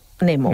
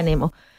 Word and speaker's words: Nemo: En español es Nemo, Nemo. Buscando a Nemo. Pero en inglés esa palabra se pronuncia Nemo. Entonces es Finding Nemo.